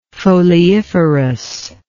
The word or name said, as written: foliiferous